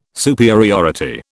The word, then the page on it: superiority: British English